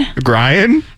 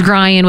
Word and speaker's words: Grian: Grian? – Ryan, no G. – Oh, Ryan. – Because well, then there's Grian with the silent G.